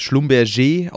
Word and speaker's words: Schlumberger: Gelernt habe ich schon vor einiger Zeit, dass es Schlumberger ausgesprochen wird, kurz SLB.